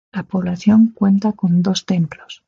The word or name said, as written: templos